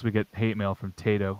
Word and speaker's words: Taito: You'll hear the manufacturer says Taito, so Taito is probably the correct pronunciation of that, and Taito is you know, but I still call it Taito.